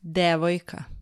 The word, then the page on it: devojka: Serbian